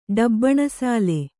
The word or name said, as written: ಡಬ್ಬಣಸಾಲೆ